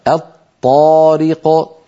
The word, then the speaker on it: الطارق: أيضا عندنا من الأمثلة كما نرى على اللوحة الطارق، هذه أصلها الطارق، أدغمنا لام التعريف في الطاء فصار النطق بطاء مشددة، أيضا لابد من أن يكون النطق بطاء مشددة غير مفكوكة، الطارق.